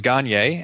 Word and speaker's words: Gagné: The original pronunciation, although not the original spelling, of my last name is Gagné, and I do use that pronunciation in certain contexts of my daily life.